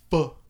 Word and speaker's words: pho: Which is so much fun to say. Pho. I had to look it up to see how to pronounce it.